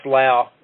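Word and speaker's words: Low: Yes, it's L-O-W. And it's pronounced Lau.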